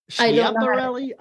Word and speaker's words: Schiaparelli: Schiaparelli? – I figured you would.